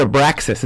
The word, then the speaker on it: Abraxas: We pronounce it Abraxas. Is that how you pronounce it, Carlos? – Yes, exactly.